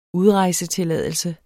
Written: udrejsetilladelse

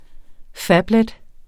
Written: phablet

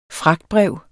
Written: fragtbrev

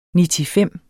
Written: nitifem

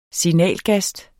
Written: signalgast

